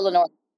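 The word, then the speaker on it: Lenoir: I don't know a ton about Lenore. Is that how you say it? Am I saying this correctly? – Diamador Lenore.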